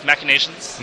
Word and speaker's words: Machinations: How do you pronounce it? – It's pretty close. Moriarty's Machinations. – Machinations.